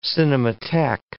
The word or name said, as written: cinematheque